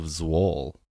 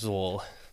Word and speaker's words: Zwolle: So now, let's dive into the liberation of Zwolle. Did I pronounce that right? – Yes. – Okay, thank goodness. I did not double-check that before we hit record. – It's Zwolle.